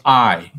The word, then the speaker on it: Ay: And then the Grand Vizier, his name was Ay.